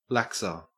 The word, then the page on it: laxer: Australian English